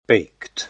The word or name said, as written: baked